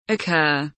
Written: occur

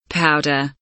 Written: powder

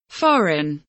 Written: foreign

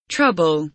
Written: trouble

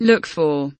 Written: look for

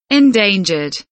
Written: endangered

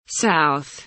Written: south

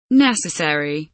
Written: necessary

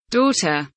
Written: daughter

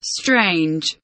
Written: strange

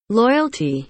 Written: loyalty

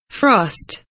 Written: frost

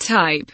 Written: type

